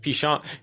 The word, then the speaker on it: penchant: Penchant, it's pronounced penchant, dumbass. Why are you trying to make it sound fancier than it is?